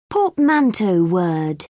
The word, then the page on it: portmanteau word: British English